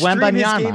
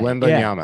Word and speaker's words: Wembanyama: and then was your third one Victor Wembanyama? – Okay. Did you say his name. I butchered that. – It's Wembanyama. I think you got it right. – Wembanyama. – Yeah. – Okay. – Wembanyama.